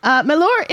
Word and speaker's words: Mellor: Anyway, Mellor is a favorite to win.